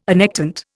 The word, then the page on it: annectent: Indian